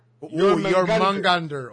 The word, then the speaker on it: Jormungandr: La J y la O con apóstrofe, your. – Se supone que sea your. Jormungandr. – Jormungandr.